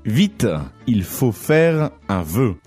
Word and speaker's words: vœu: Vœu.